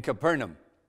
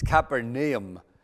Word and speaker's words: Capernaum: Capernaum, by the way, I believe the proper pronunciation is Capernaum.